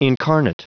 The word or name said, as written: incarnate